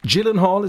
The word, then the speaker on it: Gyllenhaal: She was going out with a young buck by the name of Jake Gyllenhaal, is the correct pronunciation of his name.